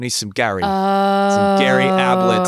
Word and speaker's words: Ablett: Ablett rhymes with tablet, yep.